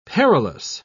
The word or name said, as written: perilous